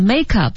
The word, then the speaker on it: makeup: 其实这个 make up 也是一个新单词，因为它在做 noun 名词的时候和做动词的时候意思是不一样，读音也不一样。 – Right. Yeah, make up is the noun, that collection of things you have to paint your face.、 Yeah. – Makeup, 大家注意听重音在前面 makeup、